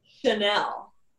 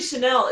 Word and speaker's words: Chenell: Chanel. – Ooh. – I know. And Chanel, like Chanel the designer, like Chanel. – Well, it's not spelled that way. It's spelled C-H-E-N-E-L-L.